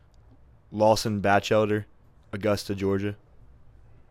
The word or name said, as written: Lawson Bachelder